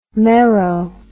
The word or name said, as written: mero